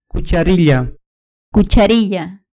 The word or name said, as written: cucharilla